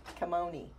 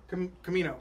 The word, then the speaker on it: Kamino: He was assigned CT-7567 on Kamoni. – Kamino.